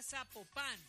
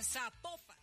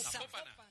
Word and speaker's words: Zapopan: Oiga, nos escriben los oyentes y que no se pronuncia Zapopan, sino que se pronuncia Zapopan. – Ah, Zapopan. – Ah, bueno, es Zapopan.